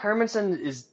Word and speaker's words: Hermansson: Jack Hermansson. Hermansson is Airman. The correct pronunciation.